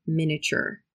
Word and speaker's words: miniature: It's spelled with an I in the middle, miniature, but we don't pronounce that I. We just say miniature. As a souvenir, I bought a miniature Eiffel Tower. So is spelled S-E-W.